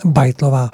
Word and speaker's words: Beitlová: Máme tady před sebou velice, velice sympatickou milou dámu Michailu Verde Bejtlo, Bajtlovou nebo Bejtlovou? – Počte se Čte se to německý Bajtlová. – Bajtlová.